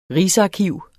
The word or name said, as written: rigsarkiv